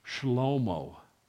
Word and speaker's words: Shlomo: Of course, it's not pronounced Solomon in Hebrew. It's pronounced Shlomo. Does not sound like Schlep.